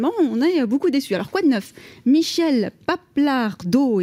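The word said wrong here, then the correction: Pappalardo: et je m'excuse si j'ai écorché son nom et si elle nous écoute. – Michel Papalardo. – Papalardo.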